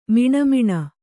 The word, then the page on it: ಮಿಣಮಿಣ: miṇa miṇa